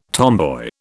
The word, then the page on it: tomboy: British English